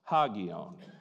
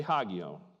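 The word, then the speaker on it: hagion (ἅγιον): The one I'm interested in is pronounced hagion. Say hagion. Good job.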